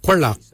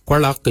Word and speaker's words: gwelyag: For your hips we say kwalak. Kwalak is your hips. Kwalak is spelled G-W-E-L-Y-A-G.